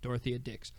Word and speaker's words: Dorothea Dix: Dorothea Dix. Yeah, I wanted to say Dorothy, but I know that's not how you pronounce it. Dorothea Dix.